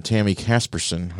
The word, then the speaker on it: Tami Caspersen: We're going to move over now to Tami Caspersen.